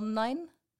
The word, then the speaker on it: NAIN: En daarnaast is zij een van de trekkers van het NAIN en daarbinnen de lead van het kernteam INET. Zeg ik het goed? – Ja, hoi, dankjewel. En je zegt het goed, we zeggen meestal NINE.